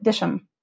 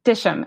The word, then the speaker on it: Dittisham: There was a town though across the river from the house called, it's spelled Dittisham, D-I-T-T-I-S-H-A-M, but the locals I learned call it Disham. – Mm-hmm. – Disham.